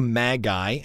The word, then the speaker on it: Magi: The Quest of the Magi, and by the way, that's the correct pronunciation, Magi. If you say Magi, you're wrong, but don't say Magi. Don't do that.